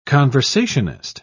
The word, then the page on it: conversationist: British English